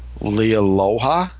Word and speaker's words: Leialoha: So I'm finally up to issue 34, which is Chris Claremont's first issue as a writer, and he co-plots with, I cannot pronounce this man's last name, it's Steve Lealoha? Is that even close? L-E-I-A-L-O-H-A. Right, yeah.